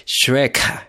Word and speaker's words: Schreck: Y en el presente, en la revelación de un árbol de Navidad gigante en Gótica, vemos una reunión del empresario Max Schreck, así se pronunciará, Schreck,